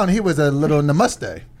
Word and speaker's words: Namaste: Namaste.